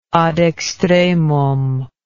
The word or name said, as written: ad extremum